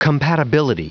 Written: compatibility

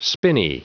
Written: spinney

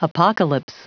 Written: apocalypse

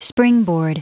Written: springboard

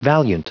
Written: valiant

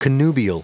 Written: connubial

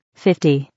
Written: 50

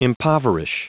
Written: impoverish

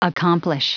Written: accomplish

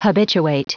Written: habituate

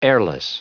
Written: airless

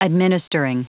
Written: administering

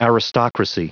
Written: aristocracy

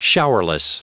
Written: showerless

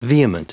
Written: vehement

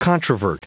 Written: controvert

